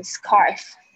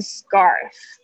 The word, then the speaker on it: scarf: 后面的的声音，scarf a r，然后 k c 的声音这边发起来很像葛的声音，因为它在 s 后面的清音，呃，不会念成 scarf，是 scarf。